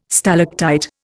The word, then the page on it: stalactite: Indian